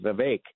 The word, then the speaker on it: Vivek: Somebody was telling me it's – I've always called him Vivek, but then he – I think he corrected me saying it's actually pronounced Vivek.